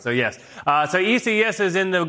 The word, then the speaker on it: Isaias: Yeah, Isaias.